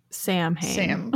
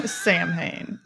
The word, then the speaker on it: Samhain: Or I've also seen that some people pronounce it Samhain. But this is spelled Samhain. So you definitely want to say Samhain. – Samhain.